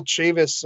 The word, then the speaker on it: Chavis: And then Michael Chavis of the Red Sox.